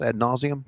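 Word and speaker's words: ad nauseam: Is that the right way to say it, Lois? Ad nauseum? That doesn't matter. Okay. She said yes.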